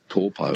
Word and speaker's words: Taupo: Now it's called Taupo. – Taupo, yeah, that's right. It's just the vowel pronunciations are a bit different. They don't really have that ow sound, like cow, meow. It's usually...